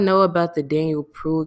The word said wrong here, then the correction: Prude: case, I'm always saying somebody's last name wrong, but I believe you say it's pronounced Daniel Prude.